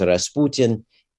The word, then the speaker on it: Rasputin: And Rasputin, in Russian pronounced Rasputin, is a fairly common surname in that part of Siberia.